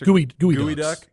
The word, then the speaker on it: geoduck: Gooey duck. – Yeah. – Duck. Spelled like geoduck. – Yeah. – Like gooey duck.